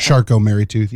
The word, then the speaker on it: Charcot-Marie-Tooth: Charcot-Marie-Tooth, yep.